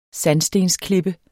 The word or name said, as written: sandstensklippe